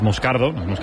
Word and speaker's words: Moscardo: Entonces, sí, como, como bien ha pronunciado Unai, es Moscardo, es brasileño, sí.